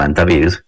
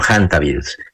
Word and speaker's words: hantavirus: Ahí está en efecto ese virus que mencionas, de esos hantavirus o hantavirus, se escribe con H y se puede pronunciar en español como hantavirus, pero es muy conocido internacionalmente como hantavirus.